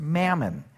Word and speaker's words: mammon: The literal word that Jesus uses there is a word that's pronounced mammon, that you can't serve both God and mammon.